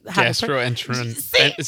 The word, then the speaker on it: gastroenterologist: But anyway, I was going to the gastroenterologist. How do we pronounce it? I was got to ask my white man what the gastroenterologist. See, I can't even do it though.